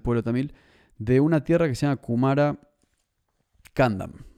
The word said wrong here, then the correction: Kumari Kandam: Kumara o Kumari, Kumari Kandam. Kumari Kandam. Así se llama.